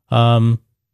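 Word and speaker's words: Smerconish: So it also says here, in the meantime, Michael Smirkanish, I guess that's the way you pronounce the name, will fill in the 9 p.m.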